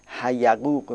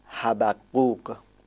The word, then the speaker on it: حبقوق: گاهی ایرانی ها به اشتباه یه نقطه دیگه در پایین میگذارن، تشدید بالا رو بر میدارن، حیقوق میخوانن، اما تلفظ صحیح این واژه حبقوق. حبقوق.